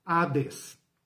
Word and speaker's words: Hades: Transliterado para o português, tem H, para sinalizar o modo de pronunciar. Hades.